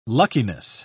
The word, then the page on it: luckiness: British English